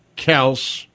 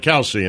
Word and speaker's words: Kelce: Now, Reed Phillips and Poncho are saying that it is pronounced Kels, but the dad got tired of correcting people. – Okay. – So they'd be like, Kelsey, and then he'd be like, okay, whatever.